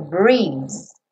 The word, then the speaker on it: breeze: And you come to me on a summer breeze. Breeze. Percebe que esse E final não é pronunciado, tá? Então, não é breezing.